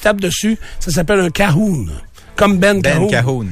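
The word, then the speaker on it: cajón: Si t'as dit Cajun, c'est parce que... – Ça s'écrit de même. – Non, c'est ça, mais ta prononciation de l'espagnol, tu l'as pas.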